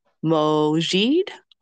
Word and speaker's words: Mojeed: So with no further ado, ladies and gentlemen, Mrs. LaShonda, and I hope I get this right, Mojeed or Mojeed? – Mojeed, you got it right. – Yay! I'm like, I knew I know the J is silent.